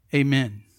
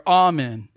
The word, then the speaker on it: amen: amen, or amen, if you want to pronounce it like that.